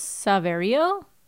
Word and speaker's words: Saverio: Speaking of Willie, the actor's name is, I'm not going to be able to pronounce this, Saverio? S-A-V-E, like save? Like save Rio? – Guerra, yeah, Guerra.